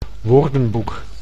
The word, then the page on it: woordenboek: Dutch